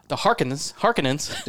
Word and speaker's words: Harkonnen: And the Harkonnens, that's hard for me to say it like that. Harkonnens. – Oh, it's a Harkonnen all day long.